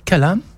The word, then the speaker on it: kalam: Et alors, kalam, mais quand on dit kalam, c'est très vaste. On ne sait pas, parce que kalam, c'est la parole.